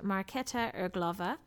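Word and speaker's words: Markéta Irglová: This stars Glenn Hansard and Marketa Erglova.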